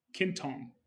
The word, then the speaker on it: Quintong: And this week we have the CEO of, and founder of PayGo Energy, Nick Quintong.